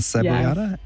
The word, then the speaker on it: cebollada: Carne frita, and let me see if I can pronounce this correctly, and cebollada.